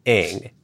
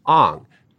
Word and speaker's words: Aang: The main character, the Avatar, is named Aang in the show. In three seasons of the show, it's always Aang. However, in the film, Shyamalan decided to pronounce it Aang.